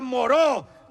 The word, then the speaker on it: Moreau: ¡Doctor Murro! ¡Doctor Murro, Carmen! – ¡Se pronuncia Moreau! ¡Doctor Moreau!